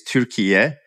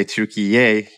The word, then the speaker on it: Türkiye: In the local Turkish language, the word Turkey for a country is Türkiye. You know, you can also say Türkiye, you know, Y-A-Y, Türkiye.